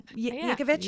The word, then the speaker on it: Yankovic: Did I say it right? Yankovic? – Yeah. I believe you did.